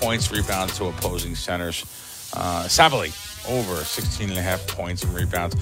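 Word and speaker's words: Sabally: Let's, Sabali. Am I saying it right? Sabali. – Sabali, yeah.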